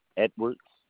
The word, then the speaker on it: Edwards: Edwards.